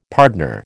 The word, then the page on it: pardner: American English